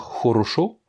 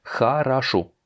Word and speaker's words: хорошо: On va pas dire хорошо, comme ça s'écrit, mais хорошо. Les O n'étant pas accentués, on va les prononcer comme des A.